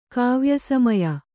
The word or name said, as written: ಕಾವ್ಯಸಮಯ